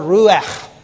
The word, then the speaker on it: ruach: The Old Testament Hebrew word ruach, it's got a sound at the end, okay? We don't have an English letter equivalent to that, but it's a guttural kind of sound, kind of like a soft CH.